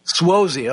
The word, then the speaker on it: Suozzi: Suozzi, okay, thank you. Suozzi,